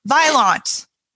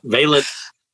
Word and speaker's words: Vaillant: So I looked it up because, like every sadly underinformed American person, I was like Vaillant, and I'm like, that's not right. So Valence.